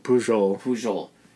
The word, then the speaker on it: Pujol: Pujol.